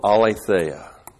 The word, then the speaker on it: aletheia: He was full of charis and He was full of aletheia. A-L, long E-T-H-E-I-A. Aletheia, you have the word truth, but it means more than truth.